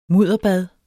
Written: mudderbad

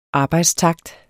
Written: arbejdstakt

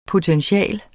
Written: POTENTIALER

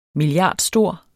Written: milliardstor

